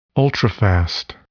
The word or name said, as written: ultrafast